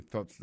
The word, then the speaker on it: thoughts: Alors, on va prononcer « thoughts » là, il faut mettre la langue dehors, comme ça. « Fa, fa, fa ».